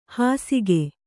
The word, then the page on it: ಹಾಸಿಕೆ: Kannada